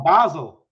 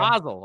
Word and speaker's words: Basel: Basel. Okay. – Basel.